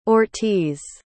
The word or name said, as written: ortiz